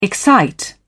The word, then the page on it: excite: British English